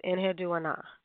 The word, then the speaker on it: Enheduanna: just trying to figure out how to pronounce her name. And it just kind of came to me, Enheddu Ana, Enheddu Ana. So I'm going to call her that. It's spelled Enheduanna. And, of course, you can hear Inanna in it. She was a high priestess of Inanna. However, her name was Enheddu Ana.